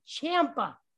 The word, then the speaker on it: Ciampa: How do you say that last name? – Ciampa. – Ciampa, spelled C-I-A-M-P-A. – Ciampa. So it is Ciampa, not Ciampa. – Somebody called. Some of them said Ciampa. Some of them said Ciampa. – What? It's not Ciampa, huh? It's definitely not Ciampa.